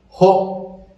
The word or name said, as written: jo'o